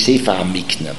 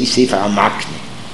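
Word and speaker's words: המקנה: Now, I also say the way it is to be pronounced is Sefer HaMikne, but when we mention it, you know, in the course of learning, we are not midagdeik to say Sefer HaMikno, we say Sefer HaMakne.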